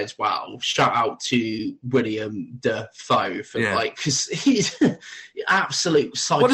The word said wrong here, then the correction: Willem: No, his first name. – William. – Nah, Willem. – Is that how you say it? The fuck? Willem Dafoe. – Yeah, not William Dafoe.